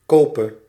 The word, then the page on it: kope: /ˈkoːpə/